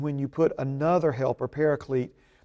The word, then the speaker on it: paraclete: Now, so another helper, the original word in the Greek is the word paraclete. And the transliteration is spelled P-A-R-A-C-L-E-T-E, paraclete.